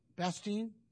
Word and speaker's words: Bastogne: And they, I hope I pronounced it. It was called Bastogne, Bastogne. And am I pronouncing that right, Jimmy? Bastogne, Bastogne. Thank you.